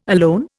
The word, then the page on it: alone: Indian